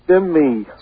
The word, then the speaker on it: Jimmy: Yeah, Jimmy from Bayshore. What's up? – Somebody from Bayshore? – Jimmy from Bayshore. – Timmy? – Jimmy. – Spell that, please. – J-I-M-M-Y.